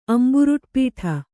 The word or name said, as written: ಅಂಬುರುಟ್ಪೀಠ